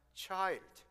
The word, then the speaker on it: chi: We all come to worship the Christ child. Also, some people use X Christmas, short for Christmas. Actually, this X is not an English letter, alphabet X. It's a Greek letter pronounced Chi.